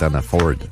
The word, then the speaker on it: Hannaford: Ben, Ford, il est marqué Ford. Ouais. C'est pas F-E-R-D, mais c'est F-O-R-D à la fin, fait que ça devrait être Hannaford.